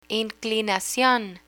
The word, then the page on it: inclinacion: Spanish